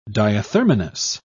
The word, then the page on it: diathermanous: British English